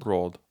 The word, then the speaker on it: Roald: Do you actually pronounce the L? I never actually knew that. Is it Road or Roald? – Roald.